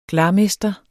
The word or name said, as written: glarmester